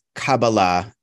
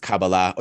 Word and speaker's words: Kabbalah: So there's different ways of pronouncing it, but Kabbalah is sort of, I guess, the way that I normally say it.